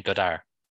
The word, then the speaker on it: Godard: Would Tarantino have done that without Goddard? Probably not. Oh, sorry, I should be saying Godard, not Goddard. We don't pronounce the D.